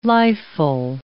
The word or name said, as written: lifeful